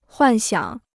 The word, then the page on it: 幻想: huàn xiǎng